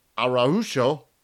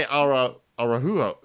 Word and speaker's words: Araujo: I don't know if that Araujo. – Was it. – Yeah. So you want to say Araujo like Spanish, but it's not. – Yeah. Araujo? – Yeah, Araujo.